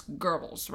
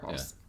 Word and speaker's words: Goebbels: A second sortie in the afternoon inconvenienced another speech by propaganda minister Joseph, and it's Goebbels, right? – Goebbels. – Goebbels. – Yeah. – There's no R in there, but I know that kind of sounds like correct. – That, right? Goebbels.